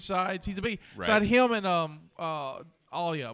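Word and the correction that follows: Aayla Secura: What's her? – Ayla Secura. – Ayla. I always mispronounce her name.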